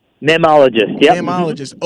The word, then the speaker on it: mammalogist: You spoke to a Harvard mammalologist. Am I saying that right? – Mammalogist. – Okay.